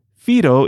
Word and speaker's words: whiro: First, Fido is spelled W-H-I-R-O, but is pronounced with an F.